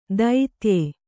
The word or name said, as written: ದೈತ್ಯೆ